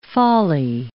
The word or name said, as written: folly